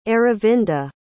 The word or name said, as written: Aravinda